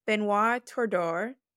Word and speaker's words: Benoit Tordeur: We have Benoit Tordeur.